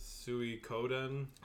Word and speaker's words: Suikoden: I guess in Japanese you would pronounce it Suikoden.